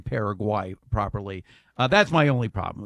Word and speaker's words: Paraguay: I correctly pronounced it Paraguay. It's Paraguay, not Paraguay.